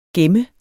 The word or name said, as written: GEMMERNES